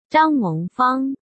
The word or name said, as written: Zhang Mengfang